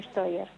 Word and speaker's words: Steuer: Tía es Tiendas Industrias Argentinas y el don Carlos, el apellido es Steuer. Está bien, Steuer se escribe, pero se pronuncia Steuer.